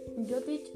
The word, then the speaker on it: Jyotish: Jyotish es un término que se deriva de la palabra Jyoti. La J en sánscrito se pronuncia como y,